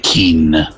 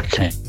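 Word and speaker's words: Kean: Ma si può fare l'opinionista e dire Kin? Non sapere che si pronuncia Ken.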